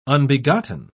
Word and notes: unbegotten: British English

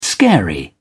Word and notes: scary: British English

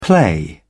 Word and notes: play around: British English

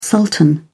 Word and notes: sultan: British English

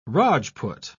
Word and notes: Rajput: British English

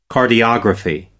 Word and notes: cardiography: American English

